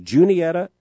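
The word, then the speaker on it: Juniata: I am told to pronounce the name of this town as Junietta, Nebraska.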